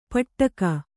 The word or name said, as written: ಪಟ್ಟಕ